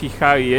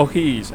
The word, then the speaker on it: O'Higgins: No es Hijagi, es un equipo medianamente nuevo. Uh-huh. O'Higgins, ahí está.